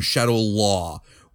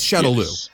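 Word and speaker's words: Shadaloo: It's Shadow Lou. It's Shadow Lou. It's spelled Shadow Lou. Every other movie, cartoon, everything that Capcom has ever produced is Shadow Lou.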